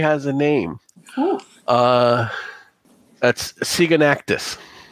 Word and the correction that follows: Siganaxius: You're going to have to spell that one for me. – No. – Spelled like it sounds. Siganactus. – It's Siganaxius. – Siganaxius.